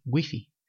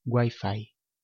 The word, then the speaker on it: Wi-Fi: Esta palabra, también de origen inglés, ya está recogida en el diccionario de español, pero su pronunciación puede ser diferente según la región del hablante. En España decimos Wi-Fi, pero en México usan la pronunciación inglesa Wi-Fi.